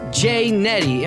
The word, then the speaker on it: J-Netti: And on today's episode, I have on J-Netti.